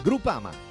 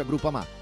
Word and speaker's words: Groupama: Groupama. Ah no, si pronuncia Groupama.